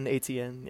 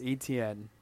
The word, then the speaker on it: Etienne: Etienne, yeah. – Yeah. I had no idea how to pronounce it, but I learned. It's Etienne.